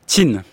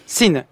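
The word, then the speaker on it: Qin: Alors, Jin, on prononce Xin. – Xin, ce qui donnera Chine.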